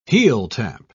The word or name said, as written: heeltap